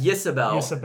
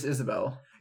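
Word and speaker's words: Ysabell: So I read it as Yisabel. – I listened to the audiobook. – They just said Isabel. – I think it's just Isabel.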